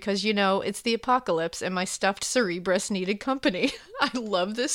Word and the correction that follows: Cerberus: Cerberus. Sorry, I said that wrong.